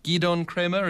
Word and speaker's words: Gidon Kremer: Riga, hometown of the great violinist Gidon Kremer, is that the pronunciation? – Right, correct, yes.